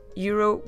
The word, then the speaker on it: euro: And euro, we say in Portuguese, euro, euro.